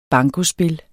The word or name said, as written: BANKOSPIL